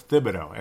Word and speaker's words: Thibodeau: And I've been calling him Thibodeau. I can't believe I'm calling him Thibodeau. It's Thibodeau. And that was the official pronunciation.